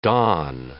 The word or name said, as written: dawn